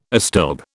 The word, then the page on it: astilbe: British English